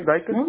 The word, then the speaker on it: Daykell: Ok, es D-A-Y-K-E-L-L. – Doble L, ok, perfecto. ¿Cómo lo pronuncia? Sí lo estoy pronunciando mal, ¿verdad? Siento. – No, no, está bien, Daikel. – Daikel, ok, perfecto. ¿Su apellido cuál es? – Pozo, P-O-Z-O.